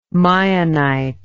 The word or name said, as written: meionite